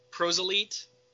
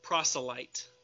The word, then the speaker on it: proselyte: When, quote, Squishy made his first appearance, when in that splash with Sinestro, I remember the guys saying, Prosolite, it is proselyte.